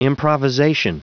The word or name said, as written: improvisation